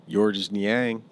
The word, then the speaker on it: Georges Niang: Georges Niang. – He said the S is silent. – The S is silent? – He said it himself, yeah.